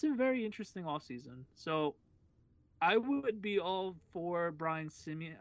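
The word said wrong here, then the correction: Sabean: or whatever. I'm butchering his name right now, but the old GM for the Giants. – Sabian, yeah. – Sabian, there we go.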